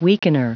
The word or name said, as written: weakener